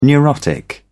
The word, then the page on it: neurotic: British English